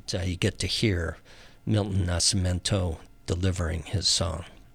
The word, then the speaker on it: Nascimento: And that's a Milton Nascimento tune. Nascimento, I should say. No I in that second to last syllable.